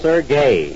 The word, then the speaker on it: Sergey: In the first place, his name is not Serge. I told you over and over to stop calling him Serge. – S-E-R-G-E. It's Serge. – It's pronounced Sergey.